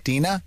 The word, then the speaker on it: Dina: And your name is Dina? – Correct. – Can you spell that for me? – D-I-N-A.